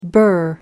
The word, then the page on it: bur: American English